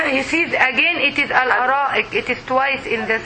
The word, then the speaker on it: الأرائك: الأرائك. – I want you to pronounce it correctly. ال. – ال. – أ. – أ. – رائك. – الأرائك. الأرائك.